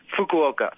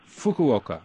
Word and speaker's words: Fukuoka: Did I pronounce the name of your town right? – Fukuoka. – Fukuoka.